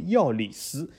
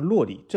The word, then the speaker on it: Lloris: 对，如果你是要用西班牙语尊重他母语的啊读法，所以克朗老师你应该称他为药里斯，啊，就是法国呃热刺门将药里斯。如果你用法语呃有些读音，你可可以称他为法国门将洛里，这都对。所以说你如果叫他洛里和药里斯，这两个都是正确的，啊，一个就是尊重他现在母国，呃，他的出生地，他现在母国，还有一个尊重他的父母的背景，对吧？